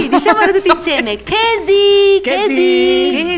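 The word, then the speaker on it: kesi (茄子): Lo sapevi che quando scattano una foto i cinesi spesso dicono la parola melanzane invece di dire formaggio, perché la pronuncia di questa parola è kesi. Kesi! Kesi, kesi, kesi!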